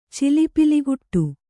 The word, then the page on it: ಚಿಲಿಪಿಲಿಗುಟ್ಟು: cili piliguṭṭu